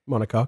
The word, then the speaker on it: monocoque: Monocoque? But French. – Cool. – C-O-Q-U-E. – Monocoque, which means...